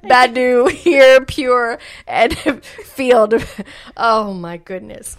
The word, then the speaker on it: Feeld: And number 10, I think it's pronounced Field, F-E-E-L-D. It just has a D.